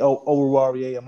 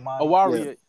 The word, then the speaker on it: Oruwariye: I always feel like, I hope I'm saying his name right, Amariye. – Oh, yeah. Oruwariye. – Oruwariye.